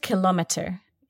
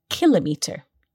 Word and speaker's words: kilometre: So the word kilometre can also be pronounced kilometre in American English, but you and I are British, so we say kilometre.